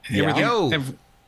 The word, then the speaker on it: Yeoh: Yeoh.